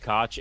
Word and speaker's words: Koch: Jason Koch, oh god, Koch is a motherfucker, but he's been around for a long time. He's a cool guy. Thank you, Koch. – And his last name rhymes with crotch, so that's great.